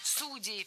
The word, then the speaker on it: судей: Судей, все верно. Это правильный вариант. Сергей порадовал. Просто многие ставят в этом слове ударение на окончание. И раньше была двойная норма. Но сейчас в отношении этого слова правила ужесточились. И нормативным считается только вариант «судей».